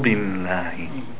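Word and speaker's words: billahi (بالله): The lam in the word Allah. Do you notice there's a shadda, right? So we pronounce it twice. Billahi, billahi, billahi. Hold the lam and it could have a shadda.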